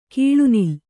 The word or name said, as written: ಕಿಳನಿಲ